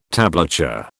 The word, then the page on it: tablature: British English